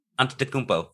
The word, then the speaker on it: Antetokounmpo: Giannis Antetokounmpo? – Yes. Now I know. Or Antetokounmpo? – Antetokounmpo.